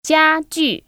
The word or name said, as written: JiaJu